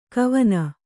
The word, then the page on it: ಗವನಿ: Kannada